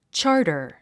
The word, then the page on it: charter: tʃɑ’ː r tə r; チャータァ